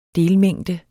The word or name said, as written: delmængde